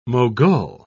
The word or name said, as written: mogul